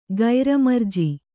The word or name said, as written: ಗೈರಮರ್ಜಿ